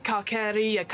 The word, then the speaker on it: Carceri: No, Carceri, hard C. I believe according to Chris Perkins, it's like incarceration. So Carceri.